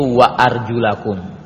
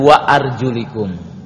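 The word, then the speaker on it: wa arjulakum (وأرجلكم): Yang pertama dibaca dengan nasab, yaitu wa arjulakum. Yang kedua dibaca dengan wa arjulikum. Walaupun hukum asal dibacanya adalah dengan nasab. Alamat nasabnya adalah di situ adalah fathah, ya. Karena isim jamak taksir wa arjulakum, ya. Memang boleh dibaca wa arjulikum, kata para ulama, dalam istilah ilmu lugah adalah lil mujawarah.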